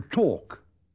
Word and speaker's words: talk: Comprobará que la letra L no se pronuncia. – To walk. – She's walking. – To talk.